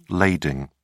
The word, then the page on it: lading: British English